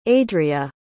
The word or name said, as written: adria